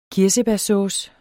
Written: kirsebærsauce

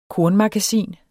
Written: KORNMAGASIN

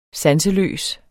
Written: sanseløs

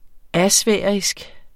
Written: asfærisk

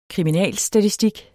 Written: kriminalstatistik

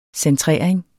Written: centrering

